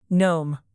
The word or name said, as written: gnome